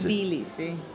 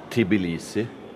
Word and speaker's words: Tbilisi: Eh, nå er vi i Tiblisi. Eh, sier jeg det riktig? – Tiblisi. Tbilisi. – Tbilisi. T-b-i. – Ja. – Uten i mellom de to ja, men, konsonantene. Sånn hvordan? T-b-i.